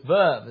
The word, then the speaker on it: vav: And as you get into each section, for instance, if we're in verses 41 through 48 tonight, and you see V-A-U with a little sign over it, that's the Hebrew word vav. You say, well, how do you know it's translated? Like, I found that on the internet where they pronounce it for you. So it's vav is how you pronounce that.